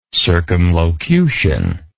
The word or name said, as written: circumlocutionist